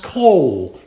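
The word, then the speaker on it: kol (כל): But the Hebrew word that Jacob used for the word enough is pronounced kol, K-O-L-E.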